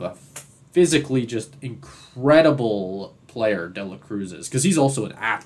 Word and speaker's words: De La Cruz: And the thing is, people are already comparing him to Ellie De La Cruz. De La Cruz. Said it right the second time.